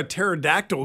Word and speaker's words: pterodactyl: Why can't you hear a pterodactyl go to the bathroom? – I don't know. – Because the pee is silent.